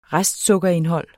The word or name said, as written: restsukkerindhold